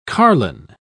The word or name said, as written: carlin